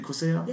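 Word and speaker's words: Crissle: Is it Chriselle? – Yes, go ahead.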